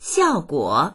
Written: xiao4guo3

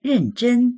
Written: ren4zhen1

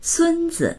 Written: sun1zi

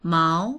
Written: mao2

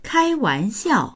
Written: kai1wan2xiao4

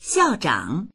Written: xiao4zhang3

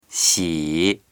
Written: xi3

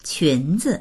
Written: qun2zi

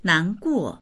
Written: nan2guo4

